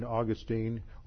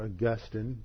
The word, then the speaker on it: Augustine: There was a debate between Augustine, or Augustine, depending on whether you're Catholic or Protestant, how you pronounce that, Augustine, who was the Bishop of Hippo in North Africa, and a British monk by the name of Pelagius.